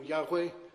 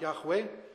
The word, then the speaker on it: Yahweh: But the Hebrew would be pronounced something like Yahweh, and is built on the word I am.